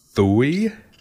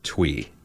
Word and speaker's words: Thuy: It looks like it should be pronounced Thuy. It's a very unique spelling, T-H-U-Y, but she pronounces it Twee.